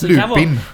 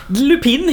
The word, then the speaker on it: Lupin: Lupin tror jag det uttalas. – Lupin. Lupin helt enkelt. Ja. Ah, L-U-P-I-N.